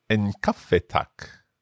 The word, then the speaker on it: en kaffe, tack: So, if we want to make it simple and we just order a coffee, we just say en kaffe tack. – En kaffe tack.